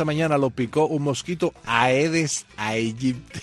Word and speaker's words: Aedes aegypti: Porque esta mañana estuvimos conversando sobre la pronunciación del mosquito. Y Bruno, pues, dice que se dice así: Aedes aegypti.